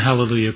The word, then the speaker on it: Hallelujah: It is spelled Alleluia in our text, but actually the A has an aspirate before it, which gives it an H sound. So actually the word is Hallelujah.